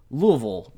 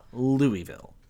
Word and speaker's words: Louisville: As someone from Louisville, Kentucky, I can confirm that he pronounced it correctly. Louisville, not Louisville. So, there you go. And when I was in Louisville, I was instructed to say it as if my mouth was full of marbles.